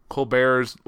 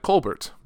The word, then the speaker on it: Colbert: And I thought about the show's origins with thinking of the Colbert Report and how Colbert's last name looked like Colbert, but he went by Colbert, which is how it's pronounced, but no one looking at that will think that,